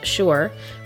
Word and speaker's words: Suir: Crookhead is on the opposite side of the Shore estuary, spelled S-U-I-R, pronounced Shore, from Hookhead, so they are the two points that guard the entrance to the port.